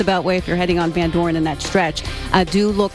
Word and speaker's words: Van Dorn: Okay, how do you say it? – Van Dorn. I'm just going to say it the way I always say it.